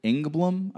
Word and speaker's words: Engblom: His name was Skip Engblom, I believe is how you pronounce his name. Yep.